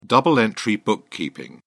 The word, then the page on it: double-entry bookkeeping: British English